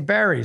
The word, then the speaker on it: Wilkes-Barre: Yeah, Wilkes-Barre, Wilkes-Barre. I've heard it pronounced both ways. – It's B-A-R-R-E, and they say Barry sometimes, huh? – Sometimes.